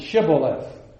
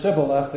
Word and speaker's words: Shibboleth: The people who were associated with Jephthah would pronounce it Shibboleth. Those who were not with Jephthah had to pronounce it Sibboleth. And if they pronounced that word Sibboleth, they were put to death.